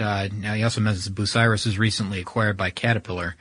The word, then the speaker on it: Bucyrus: And then to kind of complete that thought, Zach from Wisconsin wrote in and said that, his uncle works for, the company, it's pronounced Bucyrus, which is B-U-C-Y-R-U-S, Bucyrus.